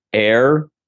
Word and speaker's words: H31R: So my five is Air. It's spelled H31R.